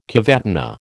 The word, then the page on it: cavatina: American English